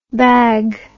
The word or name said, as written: bagh